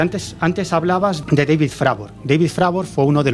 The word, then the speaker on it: Fravor: Fravor, vale. – Sería, sería la pronunciación en inglés David Fravor.